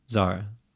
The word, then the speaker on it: Zara: Zara.